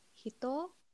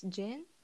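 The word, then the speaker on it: Hito (人): Like I forgot the term, but there is a different pronunciation for example, the character of Hito. – Hito, okay. It's, yeah, and the other pronunciation is Jin.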